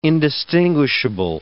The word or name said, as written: indistinguishable